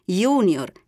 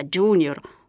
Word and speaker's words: junior: Sono invece da evitare le pronunce anglicizzate di iter, che diventa iter, junior, che diventa junior, o plus, pronunciato plus.